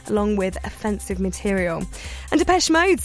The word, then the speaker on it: Depeche Mode: Depeche Mode, you did it.